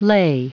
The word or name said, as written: lay